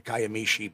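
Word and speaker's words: Kiamichi: The Kayamishi Bigfoot. – And you actually said that right.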